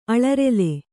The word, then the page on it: ಅಲರೆಲೆ: alarele